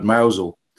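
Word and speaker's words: Mousehole: I mean, even the word Mousel, obviously spelt mouse hole, but pronounced mouse for anyone who doesn't know.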